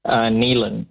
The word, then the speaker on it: Neilen: Neelan here. Can I have South Africa, please? – Yeah, of course you can. Are you and you clear? Is your name pronounced Nevan or Neelan? – Neelan. N-E-I-L-E-N. – N-E-I-L-E-N.